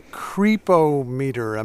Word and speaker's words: Creepometer: Okay, so you've developed something called the Creepometer. Am I saying that correctly? What is it? – That's perfect.